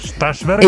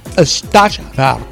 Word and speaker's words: Støjberg: Støjberg. Inger Støjberg. – Muy bien pronunciado, le digo.